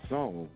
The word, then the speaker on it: Psalm: And again, Psalm starts, it sounds like it starts with an S, but it starts with a P. And then the S, P-S. The P is silent.